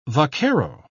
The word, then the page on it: vaquero: British English